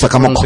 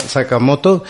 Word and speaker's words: Sakamoto: Bueno, no lo sé Sakamoko. – pronunciar. Sakamoko, ¿verdad? Para, para los mexicanos, pero sí, Ryuichi Sama- Sakamoto. Y mm,